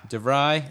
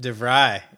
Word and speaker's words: De Vrij: I'm never going to say it right. – De Vrij. – Okay. – He's serious about success. – There you go. De Vrij, like it. – De Vrij. His name is De Vrij.